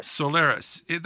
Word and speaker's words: Soliris: For example, I'm aware of the fact that a drug, and if I pronounce it wrong, please correct me, Soliris? – Soliris.